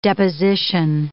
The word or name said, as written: deposition